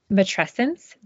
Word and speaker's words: Matrescence: Matrescence, did I say that right? – Yes.